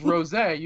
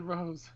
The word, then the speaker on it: rosé: It's rosé. You even said rosé wrong. – Did I say rose? – Yeah, you said rose.